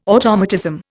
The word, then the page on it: automatism: Indian